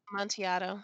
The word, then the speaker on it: Amontillado: Amontillado.